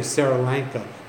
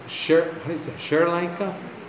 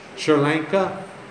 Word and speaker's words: Sri Lanka: I have a friend that I'm discipling there, and I always say it wrong. I say Sri Lanka, but it's Sri Lanka? Sri Lanka.